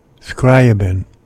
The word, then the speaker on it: Scriabin: The music will play music by Debussy and Scribner. I can't pronounce that. Scriabin at 7.